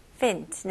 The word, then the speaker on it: infant: 발음은요, infant, fint. 네, 스펠링은 F-A-N-T이지만 F-E-N-T에 더 가깝게 생각하시면 좋을 것 같아요.